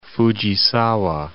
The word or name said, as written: Fujisawa